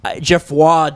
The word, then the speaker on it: Geoffroy: His name is Geoffrey de La Gassonnière. Geoffroy de La Gassonnière, and I just like butchered his last name, but I did correct myself with the French pronunciation of his first name.